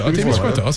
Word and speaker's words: Témiscouata: C'est-tu Témiscouata, c'est ça? – Témiscouata, c'est bon. – Exact.